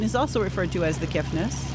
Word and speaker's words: The Kiffness: As we take a look at the Kiffness. – Also known as David Scott. – Yes, and there's no T in that, right? – There is no T in that. – So I'll say it right.